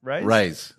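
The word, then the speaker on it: raze: But it's R-A-Z-E, isn't it? – Yeah. – Yeah. – How do you pronounce it? – Raise. – Raise. Yeah, raise. – Raise. – Yes. Yeah, it's raise, like raise. – Raise. – Like Ray's camping.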